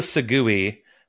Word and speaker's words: segue: What's the segue, which is how you should pronounce segue? – Yeah. – Segue is not spelled S-E-G-W-A-Y like it should be. It's S-E-G-U-E. It's a weird, weird word. Segue.